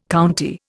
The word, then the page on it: county: Indian